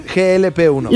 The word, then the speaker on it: GLP-1: ¿Lo dije bien? GLP-1. – Lo dijiste muy bien.